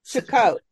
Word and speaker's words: Sukkot: Sukkot. Okay. Sukkot. I didn't say it right. – Sometimes it's spelled Sukkoth, but it's the same.